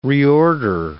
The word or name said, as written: reorder